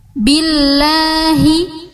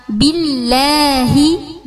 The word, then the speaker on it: billahi: Jadi kalau saya bacanya billahi sama billahi, beda enggak bunyinya? Beda kan? Enakan yang mana? Yang kedua, billahi. Kalau tadi billahi, itu Indonesia banget lidahnya, ya kan?